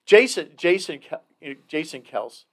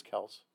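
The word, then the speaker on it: Kelce: But, really, Jason Kels, because that's how you pronounce their last name properly. It's Kels.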